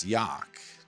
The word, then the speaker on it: Jauch: Joe, J-A-U-C-H, pronounce Yock, not the way another coach pronounced it.